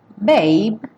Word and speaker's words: babe: Babe, sem pronunciar o E. E cuidado para não falar baby, porque essa é outra palavra, uma variação.